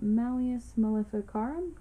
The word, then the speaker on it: Malleus Maleficarum: Anyways, the book was called Malleus Maleficarum. Did I say that right? – Mm-hmm. – Okay.